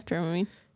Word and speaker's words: Dramamine: Is that how you say it? – Yeah, Dramamine.